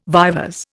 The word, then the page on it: vivers: Indian